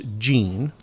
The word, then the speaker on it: Jean: Right away, we'll clear up that your name is spelled J-E-A-N, and it confuses people, and they think it's Jean. – Most people call me Jean. – Right, but it's Jean. – Yeah. – Kind of like John, J-O-H-N.